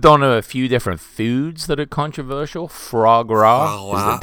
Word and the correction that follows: foie gras: Did I say that right? Foie gras. – Foie gras? – Yeah.